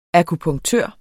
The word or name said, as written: akupunktør